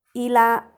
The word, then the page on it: ila: Macuiltianguis